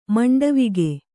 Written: ಮಂಡವಿಗೆ

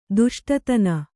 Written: ದುಷ್ಟತನ

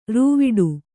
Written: ರೂವಿಡು

